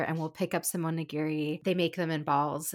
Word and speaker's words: onigiri: onigiri.